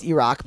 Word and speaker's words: Iraq: It's pronounced Iraq, but we'll go with it.